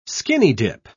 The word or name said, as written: skinny-dip